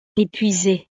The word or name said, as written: epuisee